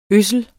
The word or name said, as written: ødsle